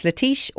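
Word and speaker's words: letiche: Some said that what Ford had seen was actually an existing folk legend, a kind of human-monkey-alligator hybrid, called the Latish, which you can pronounce Latish or Leech, whichever one, doesn't matter.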